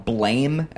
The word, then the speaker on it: Blame!: If you're looking it up, it's spelled Blame! Exclamation point, because Japanese translation. But it's pronounced Blam. It's supposed to be like a gun going off, like boom, blam, blam.